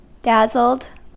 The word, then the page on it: dazzled: American English